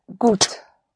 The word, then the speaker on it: gut: The gut is spelled G-U-T. The U always is pronounced like a double O in English, like in food. Gut. So once more, it is good. – Es ist gut. – Right. Es ist gut.